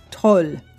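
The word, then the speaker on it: Tolv: It is the Toll. Now, Swedish folks, if I got that wrong, because it's T O L V,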